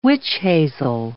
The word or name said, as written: witch hazel